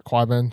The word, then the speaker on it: Qyburn: So then Tyrion moves up, heads towards the wall, and then Qyburn, is it Qyburn? – Qyburn, thank you.